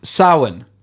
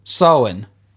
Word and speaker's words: Samhain: It has its roots in, I don't know how to pronounce this, Rory, I'm going to be real with you. Samhain. Samhain. Uh, this is the ancient pagan festival. It's actually spelt Samhain confusingly.